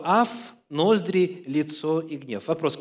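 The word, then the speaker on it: аф (אף): Ну, во-первых, звучит оно так. Слово «гнев» – это «аф». «Аф». «А» и «а» F, если по-русски произносить так, транслитерация.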